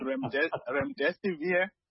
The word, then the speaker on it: remdesivir: It's called Remdesivir.